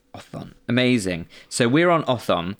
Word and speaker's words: otthon: So here it's spelled O-T-T-H-O-N. – Othon. I like that a lot. – Because it sounds a bit like a thorn.